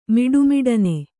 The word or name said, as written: ಮಿಡುಮಿಡನೆ